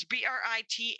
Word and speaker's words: Britainy: And her name is spelled like Britain, and it's Britainy.